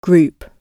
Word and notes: group: British English

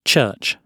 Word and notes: church: British English

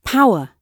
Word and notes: power: British English

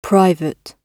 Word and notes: private: British English

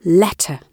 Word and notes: letter: British English

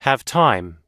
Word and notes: have time: American English

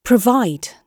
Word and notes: provide: British English

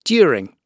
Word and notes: during: British English

